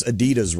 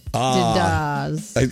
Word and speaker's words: Adidas: Americans, we pronounce Adidas wrong. Did you know that? – What are we supposed to say? – So we stress the second syllable, the D. What the way it's actually supposed to be pronounced? It's the ah.